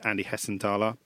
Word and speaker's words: Andy Hessenthaler: They've got a squad that includes the likes of Adi Akinbaye, who we'll come to, and their club captain and future manager, Andy Hessenthaler. Probably said that wrong. – No, that's correct. – That's correct. Good.